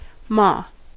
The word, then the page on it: ma: American English